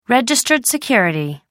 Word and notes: registered security: American English

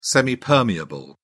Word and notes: semipermeable: British English

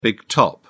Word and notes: big top: British English

